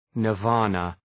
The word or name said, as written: nirvana